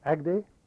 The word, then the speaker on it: Agde: And southern France, it was called Point A-G-D-E, Agde.